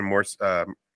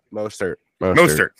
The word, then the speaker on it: Mostert: Like, he was stopping Morissette. What is his name? How do you say his name? – Mostert.